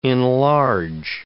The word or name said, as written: enlarge